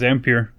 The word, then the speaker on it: Ampere: So they're Ampere, or am I saying that right? Ampere? I thought it was Ampere. – Ampere, okay, Ampere. So that whole technology that they're working on wasn't for home-based users.